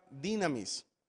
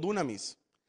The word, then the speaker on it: dynamis: La palabra poder en el griego es la palabra dynamis Algunos lo pronuncian como dunamis, pero es la palabra dynamis, es con Y.